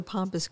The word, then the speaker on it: pampas: So, one you mentioned that they say is a symbolic of it is this pampas grass. Is that how it's pronounced? – Yeah, it's a pampas grass.